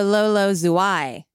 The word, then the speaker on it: Zouai: You meant to say Lolo Zouai. – Oh, I said it wrong? That's my Southern accent. – You emphasized the zoo instead of the I. – So Lolo Zouai.